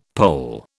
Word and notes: Pole: British English

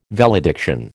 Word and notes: valediction: American English

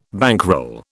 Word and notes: bankroll: British English

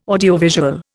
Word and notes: audiovisual: Indian

